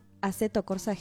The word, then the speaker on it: Assetto Corsa: Assetto Corsa GT.